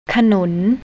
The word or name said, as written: kanoon